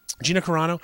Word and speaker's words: Carano: Gina Carano.